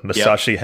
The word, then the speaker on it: Masashi: Mashima Hamazu. – All right, you really butchered that one. So Masashi, yep.